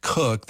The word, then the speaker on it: Koch: Her last name is spelled K-O-C-H, pronounced Cook.